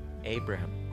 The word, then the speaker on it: Abraham: نام خانوادگیش رو شکل خاص خودمون تلفظ میکنیم پس آبراهام لینکلن آنچه آنان میگویند و آنچه در انگلیسی گفته میشه ابراهام